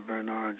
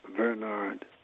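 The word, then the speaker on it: Vernard: Mail it to Dr. Bernard Johnson.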